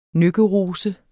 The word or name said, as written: nøkkerose